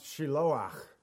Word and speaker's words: Shiloah: That word really is pronounced Shiloah, believe it or not.